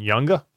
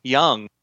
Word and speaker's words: Junge: His name, if you looked at it and you didn't know how to pronounce it, it is Frederick Junge, J-U-N-G-E. Hmm. You know how to pronounce his name? – I don't. Younga? – His name, as pronounced, is Sam Young.